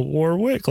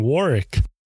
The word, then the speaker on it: Warwick: And I was saying, I've always thought it's, you know, Warwick, like how it's spelled. And she's saying Warwick.